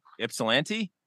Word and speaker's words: Ypsilanti: They're in uh I'm gonna say this wrong. Ypsilanti, yeah, Michigan. Said it right.